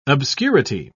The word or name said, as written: obscurity